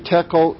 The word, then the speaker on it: tekel: Mene, mene, tekel, upharsin. Mene means numbered, and it's mentioned twice, which shows that he has Belshazzar's kingdom has been numbered and it's time's up. Tekel, which rhymes with shekel, tekel is to weigh coins.